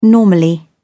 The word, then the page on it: normally: British English